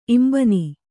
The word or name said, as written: ಇಂಬನಿ